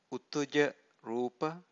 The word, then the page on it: utuja rupa: Pali